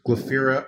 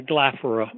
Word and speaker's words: Glaphyra: I'd like to move now to the next figure you focus on, and if I mispronounce the name, please correct me. It's Glaphyra of Cappadocia. – Yeah, Glaphyra. – Glaphyra, excuse me. – Would be better.